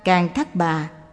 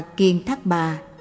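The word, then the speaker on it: Càn Thát Bà: Càn Thác Bà, Ca Lầu La, Khẩn Na La và Ma Hầu La Già. Tên gọi này cũng được đọc là Kiền Thác Bà, Kiển Đà La, dịch nghĩa là hương thần, là loài chuyên về âm nhạc trên cung trời đế thích.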